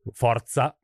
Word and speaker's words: Forza: Forza. – Forza? – Yeah. – Like a fort. – Forza Bears. – Like force. – Forza Bears. – Yeah. Forza with the Z.